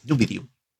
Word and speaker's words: Numidium: Cuando llevamos la carta a un cuchillo nos pide ayudarle a buscar una llave para resucitar a Numidium. Así se pronuncia, Numidium.